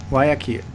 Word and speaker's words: Guayaquil: Guayaquil? – Guayaquil.